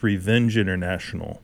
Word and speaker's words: RVNG International: Visible Cloaks with the track Slippage from their most recent album, Paradescence, on RVNG International, or perhaps it's always pronounced Revenge International. Though sometimes when I look at it, it's, it speaks to me roving. Roving International? I think it's Revenge.